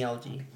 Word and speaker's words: LG: It's not really like my name that I golf with, but everyone calls me LG.